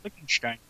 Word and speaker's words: Liechtenstein: That is Liechtenstein, isn't it? – Yeah, Liechtenstein. Yeah.